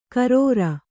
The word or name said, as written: ಖರಾರಿ